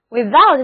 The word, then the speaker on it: without: 所以 without, W-I-T-H-O-U-T, without 的意思就是没有，在外。字母组合 OU 要读作 OW without, without.